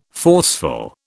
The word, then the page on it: forceful: British English